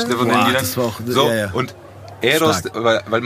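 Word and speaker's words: Héroes: Nee, Eros. Ja, das H spricht man nicht.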